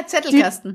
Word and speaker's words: Zettelkasten: I decided as part of this project, I was going to start a Zettelkasten. I'm saying that wrong. It's a German word. – Yeah, Zettelkasten.